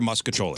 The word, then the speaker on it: mostaccioli: So M-O-S-T. – Italian pasta pronunciation. – So M-O-S-T-A-C-O-L-L-I. We pronounce it here, muscacholi. – Two C's. Right? I thought there was two C's. M-O-S-T-A-C-C-I-O-L-I.